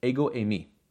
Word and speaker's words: ego eimi: We actually find that the statement here, "I am," made by Jesus, was recorded in Greek, and it's pronounced in the Greek "ego eimi."